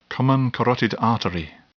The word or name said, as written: common carotid artery